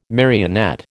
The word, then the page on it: marionette: American English